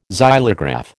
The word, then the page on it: xylograph: American English